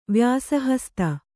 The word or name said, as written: ವ್ಯಾಸಹಸ್ತ